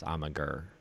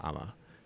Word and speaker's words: Amager: It looks like it would be pronounced Amager, but from how it's spelled, it's actually pronounced Ama, named after the island that they're on.